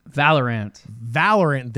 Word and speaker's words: Valorant: Valorant, there we go.